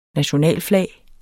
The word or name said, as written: nationalflag